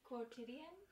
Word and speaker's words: quotidian: Quintonian. – I don't know what that means. – Quintonian? – Go ahead and say it correctly. Quotidian? – Quotidian. Oh, okay.